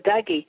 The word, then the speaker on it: Dougie: We've seen her doing, let me see if I can say it right, the Doogie, did I say it right? – The Dougie.